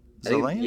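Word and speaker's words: Zaillian: How do you pronounce it? Zalian? – Zalian? Yeah, Zalian or Zalian.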